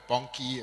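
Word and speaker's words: Bonnke: Well, for those of you that do not know him, that is Reinhard Bonke or Bonke, and they've got a couple of ways of pronouncing that.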